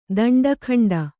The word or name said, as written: ದಂಡಖಂಡ